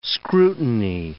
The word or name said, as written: scrutiny